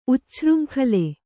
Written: ಉಚ್ಛೃಂಖಲ

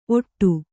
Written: ಓಡ

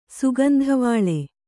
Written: ಸುಗಂಧವಾಳೆ

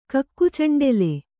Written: ಕಕ್ಕುಚಂಡೆಲೆ

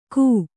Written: ಘೇ

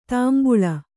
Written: ತಂಬಳಿ

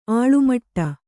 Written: ಅಲೆಮಾಡು

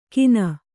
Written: ಕೀನ

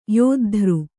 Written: ಯುದ್ಧ

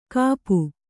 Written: ಕಾಪು

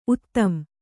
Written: ಉತ್ತಂ

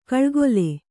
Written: ಕೌಳುಗೋಲ್

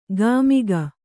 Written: ಗಾಮಿಗ

